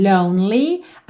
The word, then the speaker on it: lonely: Are you somewhere feeling lonely? Atenção a essa pronúncia da palavra lonely, ok? Que esse E do meio não é pronunciado, então você vai do N pro L. Lonely.